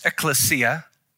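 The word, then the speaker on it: ekklesia (ἐκκλησία): The word is pronounced ekklesia.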